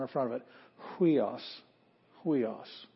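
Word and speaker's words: huios (υἱός): Huios. That's why we pronounce it huios.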